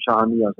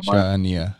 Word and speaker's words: Shahania: Het was, volgens mij was het zo dat, dat de club van de Jong al Shahania, zeg ik dat goed? – Ik zou hem Shahania zeggen. – Shahania.